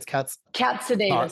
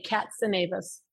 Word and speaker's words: Katsanevas: It's Katsanevas. It's okay. I've been dealing with this my whole life. It's like a cat, but with a K, Katsanevas.